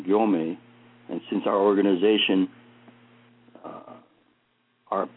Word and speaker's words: Gyomei: So he changed the spelling from M-E-I to M-A-Y so that it would be easier for people to say Gyomei when they see that. And that's just a kind of interesting history in terms of his Dharma name.